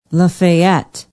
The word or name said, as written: lafayette